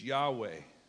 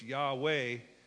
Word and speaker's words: Yahweh: That's really a man-made term, because the real reality of that is it's Yahweh, Y-H-W-H, no vowels. The scribes would come along in those days and they would point a pronunciation beneath the bottom line. So it's Y-H-W-H, and they put a there, somewhat similar to ours, and an E, so it's Yahweh, and we somehow Latinized that.